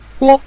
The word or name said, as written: guo5